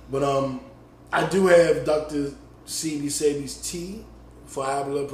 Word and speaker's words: Sebi: Sebi. You pronounce the E.